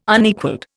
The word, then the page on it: Unequalled: Indian